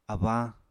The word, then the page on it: abañ: Apatani